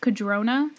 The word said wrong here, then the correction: Candrona: There's an N. – I speed read, so I, like, mispronounce things in my head a lot. – Candrona, Candrona is a very, like, impractical word to say.